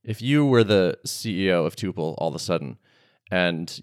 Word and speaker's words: Tuple: So am I pronouncing that right, by the way? Tuple or tuple? – It's actually tuple. – Ah, tuple. My bad. And yeah. Everyone was listening. – I mean, that's not fair. We pronounce it tuple. Tuple is a totally legitimate pronunciation of that word as well.